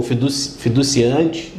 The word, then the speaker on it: fiduciante: o fiduciante, né? Fiduciante, estou falando certo? – É isso mesmo? – É.